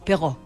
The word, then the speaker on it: Perrault: Perrault es como atrás. – Al final. – Es una cosa importante para el. – Charles Perrault. – que uno realmente no lo toma tan en cuenta. – Es cierto. – En la pronunciación de, o el intento de pronunciación de otros idiomas, que es donde tiene que resonar la letra.